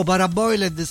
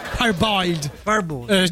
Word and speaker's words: parboiled: Scritto paraboiled, scrivo bene, mi Paraboiled, si legge però? Parboiled. Giustamente che in inglese.